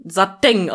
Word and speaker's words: Satin: Wusstest du, dass Satin im Deutschen Satin ausgesprochen wird? Dass das die korrekte Aussprache ist? Satin? – Ja, also ich weiß, dass man es im Deutschen so spricht, aber es ist halt, weiß ich nicht. – Das ist die korrekte Aussprache im Deutschen tatsächlich.